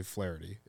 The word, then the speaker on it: Flaherty: But is the MLB pronunciation, is it actually Flaherty? Is that how they have it in the book off the top of your memory? – I believe so. It should be, because that's the correct way to announce it.